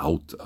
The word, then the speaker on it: Howth: Auf jeden Fall ist diese Kirche genutzt worden als Pfarrkirche auf der Insel für den Ort Howth, oder eigentlich spricht man in Haut aus, habe ich heute gelernt.